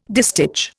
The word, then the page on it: distich: Indian